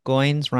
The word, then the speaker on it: Goins: Last name Goins rhymes with coins. But for all of middle school, they thought my name was Groins.